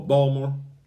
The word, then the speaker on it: Baltimore: That's like white people, they pronounce Baltimore, Balmer.